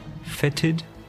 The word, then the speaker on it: fetid: How do you pronounce that? Fetid? Fetid? – It can't cloud. – F-E-T-I-D, that's the word. – F-E-T? – T-I-D, yeah, I've never read it before.